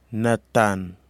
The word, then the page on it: nathan: kwamera